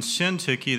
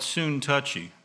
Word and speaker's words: Syntyche: Their names are Euodia and Syntyche. That's how I pronounce it. I've heard it pronounced Syntyche. Sintaich. I've heard it pronounced all different kinds of ways. Some people call it soon touchy.